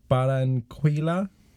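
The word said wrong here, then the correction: Barranquilla: This is how they dance in Baranquilla.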